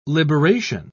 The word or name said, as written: liberation